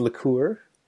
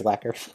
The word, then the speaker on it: lacquer: lacquer. Is that am I saying that right? Or a different finish? – I, I believe it's liqueur. – No, just kidding. It's, it's, I think it's lacquer. yeah, yeah, and so it's it's the same core, same wood, uh, but they're you know like thirteen dollars less.